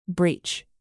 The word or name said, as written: breech